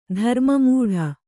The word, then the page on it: ಧರ್ಮಮೂಢ: dharma mūḍha